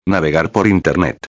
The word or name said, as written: navegar por internet